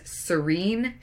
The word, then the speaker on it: Serene: So you have our captain of the ship, Miss Serene Shinfa. It is pronounced like the word Serene is.